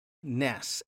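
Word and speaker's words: Ness (נס): So starting at number one on your handout, we have to know something about the word for banner and the Hebrew word Ness, N-E-S, we would pronounce it.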